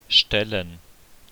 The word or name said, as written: stellen